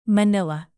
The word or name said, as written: manila